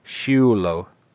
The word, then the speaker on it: Schiullo: I'll spell that surname, by the way. S-C-H-I-U-L-L-O. Shulo.